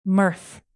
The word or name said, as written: mirth